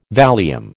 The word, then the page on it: Valium: American English